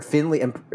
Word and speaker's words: Finlay: Or Finley. It's probably pronounced Finley, but it's spelled Finlay.